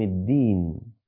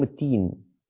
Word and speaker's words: الدين: الحمد لله رب العالمين، الرحمن الرحيم، مش الرحمن، الرحمن الرحيم، مالك يوم الدين. بعض الناس ينطقونها مالك يوم التين، غلط.